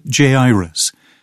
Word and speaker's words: Jairus: I know that there are various pronunciations of Jairus. Sometimes it's Jairus, sometimes it's Jairus, sometimes Jairus. The Scriptures Committee has asked that I pronounce it Jairus.